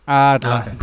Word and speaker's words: Ardland: I even get the first syllable because it's double A. Ardland.